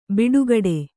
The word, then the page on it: ಬಟ್ಟೆಗೆಡು: kannada